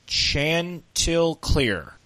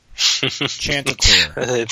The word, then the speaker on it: Chanticleer: Here's how you pronounce it. Chanticleer. Chanticleer.